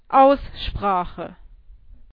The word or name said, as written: Aussprache